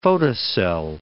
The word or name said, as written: photocell